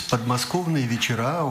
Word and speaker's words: Podmoskovnye vechera: Justement, le titre original en russe, je le prononce en russe, Podmoskovnye vechera, on peut traduire comme les soirées près de Moscou, du banlieue de Moscou.